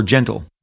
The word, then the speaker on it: Gentile: Needless to say, I was surprised when I received the call from the owners of the property, AGE, Abrams Gentile Entertainment, or Gentle, but it's spelled Gentile, like a Gentile in the Bible.